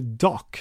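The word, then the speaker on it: Dach: Men, eh, och jag säger att de tar en forward bara för att Kirby Dash, eller Kirby Dock som han ska uttalas, eh, finns tillgänglig i åttonde valet.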